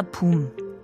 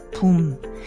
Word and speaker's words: 붐: 문예 단체 미술 강좌 붐. And so I, I'm sure you guys are probably like, oh, that boom, it sounds like the word boom in English. That's because it is. It, it's boom.